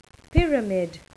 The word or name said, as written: pyramid